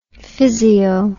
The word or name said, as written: physio